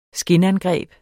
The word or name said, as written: skinangreb